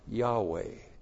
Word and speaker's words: Yahweh: Now, if you actually pronounce this in the Hebrew, you'll be pronounced Yahweh. You don't ever see that word. You have to know Hebrew to know that.